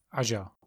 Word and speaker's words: Aja: Takže nejdřív teda krátce asi k té kořisti, to točil Alejandro Aja, nebo jak se čte, Acha. – Aja. – Aja. Možná.